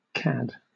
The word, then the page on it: cad: British English